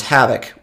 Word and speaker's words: havoc: Havoc, the havoc is spelled H-A-V-O-C, right? And the O in the word havoc, the way that I say it, sounds like an I.